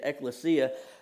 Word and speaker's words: ecclesia: Or they might say ecclesia, depending on the teacher that you got. But the right way to say it is ecclesia.